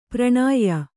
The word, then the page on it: ಪರಿಣಯ: pariṇaya